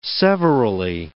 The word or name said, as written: severally